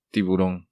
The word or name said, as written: Tiburon